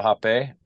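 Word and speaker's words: rapé: No, that you don't have to do anything, but um, it's called hape, and it's spelled R A P E, like rape, but mm-hmm.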